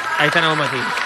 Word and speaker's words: Aitana Bonmatí: Anthony Bonmatí. – Aitana Bonmatí.